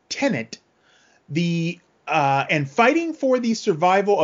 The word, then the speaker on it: Tenet: So Tenet is the I keep saying Tenet, and there's no second N in there. I gotta pronounce it right.